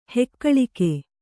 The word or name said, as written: ಹೆಕ್ಕಳಿಕೆ